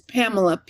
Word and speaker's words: Pamela: And I'm going to spell that as Pamela, P-A-M-E-L-A, Deneuve, which is my name.